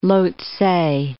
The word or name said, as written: Lhotse